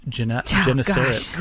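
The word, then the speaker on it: Gennesaret: Yeah, and you pronounced it Genesaret correctly. – Now it's going to be... – Don't mess it up. – Genesaret? – Okay, Luke 5, 1 through 11.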